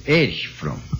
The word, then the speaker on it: Erich Fromm: Irit Fromm. Falei mal, né? Erich Fromm.